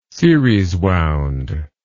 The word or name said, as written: series wound